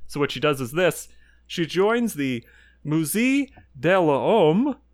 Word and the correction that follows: Musée de l'Homme: which I did not pronounce right, but is like M-U-S-E-E-D-E-L-H-O-M-M-E. And evidently it's a big part of the French. – You honestly got pretty close. – How do you pronounce it? Do you know? – Musée de l'Homme. – Okay, good. That's far prettier than what I said.